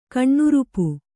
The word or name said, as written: ಕಣ್ಣುರುಪು